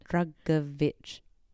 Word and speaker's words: Drugovich: Drugovich.